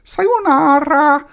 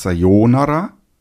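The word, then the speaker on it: Sayonara (さよなら): Und jetzt kommen wir mal zu sowas wie einer Verabschiedung da glauben viele, sie wissen das und sagen, das ist doch Sayonara. Und da muss man leider sagen, erstens ist es Sayonara und zweitens wird es nicht nur anders ausgesprochen, sondern bedeutet etwas ganz anderes.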